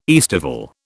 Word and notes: aestival: British English

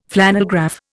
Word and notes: flannelgraph: Indian

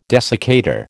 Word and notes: desiccator: American English

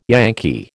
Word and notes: Yankee: American English

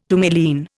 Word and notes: tourmaline: Indian

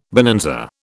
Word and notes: bonanza: British English

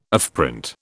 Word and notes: offprint: British English

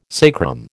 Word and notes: sacrum: American English

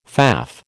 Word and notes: faff: American English

